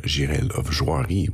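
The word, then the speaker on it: Jirel of Joiry: Moore, which is, of course, the French translation of Girel of Joiry, which, as you'll notice, I'm pronouncing in a very specific way because French is my first language and the character is nominally French.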